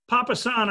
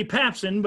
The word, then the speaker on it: Papasan: Papasan, I think, is what it would be the pronunciation. – Okay. – Because it has an A. If not, it would be Papsan, but it looks like Papasan.